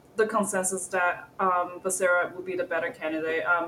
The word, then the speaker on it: Becerra: And then we have, I'm going to butcher this name, Javier Becerra, is it? – That's Becerra. – Right.